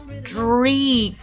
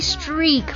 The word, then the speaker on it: streak: 先看着这个音标大家读一下， streak streak 长元音 e streak，非常容易读的一个单词， streak s-t-r-e-a-k streak 很容易读成 streak 啊，因为我们都知道牛排那个单词是 steak，对不对？都有 e-a-k 啊，但是它是发作 e streak，名词表示条纹或者一条一条这个痕迹 streak，比如说窗户上的这个一条一条的这个污迹 dirty streaks on the window，月球表面上有这样一些黑色的条痕条状的东西啊。